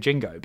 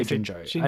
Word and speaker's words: Jinjo: Well, I thought they said Jingo, but they don't. They say Jinjo. And it's spelt with a J rather than a G.